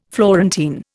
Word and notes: Florentine: Indian

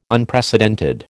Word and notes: Unprecedented: American English

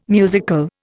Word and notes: musical: Indian

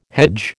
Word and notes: hedge: American English

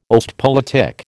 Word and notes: Ostpolitik: American English

